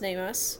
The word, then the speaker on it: NamUs: which is pronounced NamUs, but it's spelled like capital N, lowercase am, capital U, lowercase s.